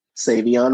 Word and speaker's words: Savion: Would not have guessed that's how you pronounced your name, because the way I'm looking at it, I would have said Savion. – So yeah, most people think it's like more French than it actually is.